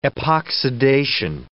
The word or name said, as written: epoxidation